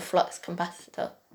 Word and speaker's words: flux capacitor: That's true, but at the same time, if you did have the flux capacitor, is that how you say it? – Yeah.